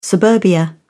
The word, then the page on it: suburbia: British English